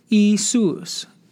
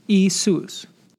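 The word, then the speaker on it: Iēsous: In Greek, it is pronounced Isus. Isus.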